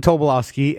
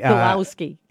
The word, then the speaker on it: Tobolowsky: Stephen Tobolowsky. – Tobolowsky.